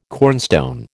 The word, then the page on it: cornstone: American English